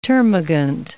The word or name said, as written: termagant